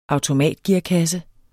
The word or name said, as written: automatgearkasse